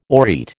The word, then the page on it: aureate: American English